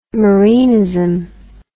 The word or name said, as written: Marinism